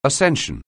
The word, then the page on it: ascending: anglais